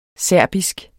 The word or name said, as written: serbisk